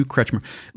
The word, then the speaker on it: Kretschmer: I don't know that the average person cares, but in looking at this work, wait, how do you pronounce his name? Kretschmer? – Yeah. Yeah. I think it's Kretschmer. Yeah. – Hugh Kretschmer.